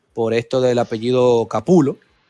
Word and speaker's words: Capullo: Exacto, podemos pasar a Greg Capullo, Capulo. Eh, el, Capullo, tío, oye, Capullo. Claro. que si, mal no creo que lo he escuchado es Capulo, ¿verdad? Capulo, es la pronunciación correcta.